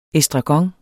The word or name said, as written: estragon